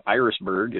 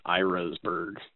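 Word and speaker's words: Irasburg: And I wanted to point out that the town of Irisburg is pronounced as though the name of the flower, Iris, is in it, rather than Irisburg, as it might seem.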